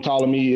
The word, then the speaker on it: Ptolemy: I don't know why I say Ptolemy, but the P is silent. From Ptolemy is that's why he wanted to be consecrated as a god.